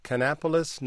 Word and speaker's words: Kannapolis: Let me ask Lisa from, is it Kannapolis, North Carolina? Is that how you say it? – That's right.